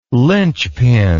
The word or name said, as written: lynchpin